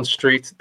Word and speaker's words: Streete: So, as I said, I'm Leon Street, the only one that exists, and it's because of the silent E at the end of my surname.